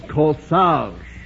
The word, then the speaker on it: corsage: Miss Canova, the word is corsage. The A-G-E is pronounced arge.